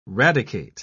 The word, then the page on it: radicate: British English